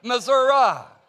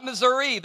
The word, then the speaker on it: Missouri: And that's the correct way to say it, Missouri. It's not Missouri. There's no E on the end of Missouri.